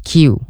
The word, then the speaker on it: Q: And like Kuwewe. – There's that word. They like 90% of them always mispronounce it into like kuwewe or something like that.